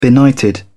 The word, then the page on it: benighted: British English